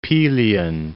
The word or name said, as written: Pelion